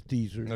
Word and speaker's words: Deezer: Did I say that right? Is Deezer? – Yes, it's Deezer.